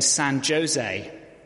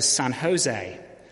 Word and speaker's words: San Jose: And he said, "Well, I'm going to stay in San Jose." An immigration officer informed him and corrected him, explaining that in the US, when they say the J, they pronounce it as a H.